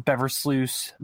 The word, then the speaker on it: Beversluis: Uh, Brian B R I A N Beversluis B E V E R S L U I S.